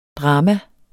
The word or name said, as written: DRAMAS